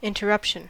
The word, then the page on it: interruption: American English; /ˌɪntəˈɹʌpʃən/